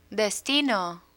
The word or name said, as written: destino